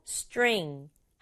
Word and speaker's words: string: 글자가 이렇게나 많은데 한 박자예요. 스트링 아니고 string.